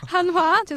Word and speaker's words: 한화: 그리고 상위권 팀으로는 우선 NC, 삼성, 두산, 넥센, 그리고 중위권에는 하나, 한화, SK. – 아, 발음 좀 잘해줘요. – 한화 앞인가요? – 한화, 죄송해요. 한화, SK, 기아, 롯데, 그리고 하위권에는 LG와 KT가 있는데요.